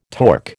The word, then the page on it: torc: American English